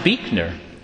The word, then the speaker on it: Buechner: It is pronounced Buechner.